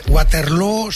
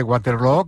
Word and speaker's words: Waterloo: Pues no lo es, desde luego, pero ha hecho mucho daño en la pronunciación de Waterloo, que se pronuncia igual que Feijóo.